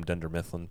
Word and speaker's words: Dunder Mifflin: which the party planning committee from Dunder Mifflin took care of that.